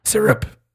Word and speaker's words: Sarap: How do you say Sarap Shop? – Syrup. Syrup shop.